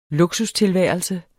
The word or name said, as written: luksustilværelse